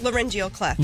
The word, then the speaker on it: laryngeal cleft: Laryngeal cleft. – Laryngeal cleft awareness day. Tell us what that is. – So a laryngeal cleft is like a hidden airway disorder.